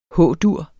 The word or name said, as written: H-dur